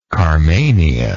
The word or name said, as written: carmania